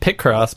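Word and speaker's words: Picross: And you had talked about P-Cross or Pi-Cross. How do you pronounce that? – I always just said Pic-Cross, but yeah, I don't know. – Pic-Cross. Okay, that makes sense because it's a picture.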